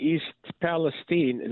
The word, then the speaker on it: East Palestine: But on February 3rd in East Palestine, this is how they pronounce it there, a small town of about 5,000 residents in Ohio near the Pennsylvania border, a freight train derailed.